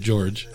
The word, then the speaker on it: Jorge: And George is like, which I found out, he spells it Jorge, pronounces it George.